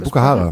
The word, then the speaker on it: Bukahara: Ich habe einen Songwunsch für die Playlist und zwar heißt der Song Afraid No More von Bukahara. Vielleicht habe ich es völlig falsch ausgesprochen. – Nee, Bukahara.